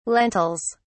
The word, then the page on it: lentils: British English